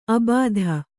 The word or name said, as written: ಅಬಾಧ